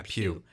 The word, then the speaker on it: Pugh: Yeah, Pugh. – Florence Pugh, like Pewy. – Or like a church pew.